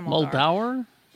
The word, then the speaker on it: Muldaur: Imagine being a fan of the original series and seeing Diana Muldaur. I cannot remember how to pronounce her name. Diana Muldaur? – Yeah. – It's Muldaur. Yeah.